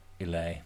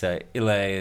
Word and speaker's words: Islay: So you don't pronounce the S. See, and that's why this is why you get confused. – That's why I wasn't thinking of pronouncing the D. – No, it's Islay is the way to pronounce I-S-L-A-Y.